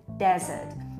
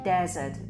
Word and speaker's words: desert: Czyli wiecie, kiedy ja mówię, że akcent w wyrazie pustynia jest na pierwszą sylabę, to mówię desert. Wyraźnie słyszycie, jak E, mówię desert, czyli te pierwsze D jest właśnie z takim naciskiem, ono jest mocniejsze.